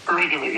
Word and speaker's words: Reykjavik: Did I pronounce that Reykjavik or is it Reykjavik? Yeah. – Oh, okay. Good.